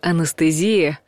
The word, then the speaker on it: анестезия: В заимствованных словах часто бывает, что писать нужно «е», а говорить «э». И анестезия от греческого «анастасия» – нечувствительность, не стала словом исключением. Даже если произнести хочется помягче, постарайтесь не поддаваться.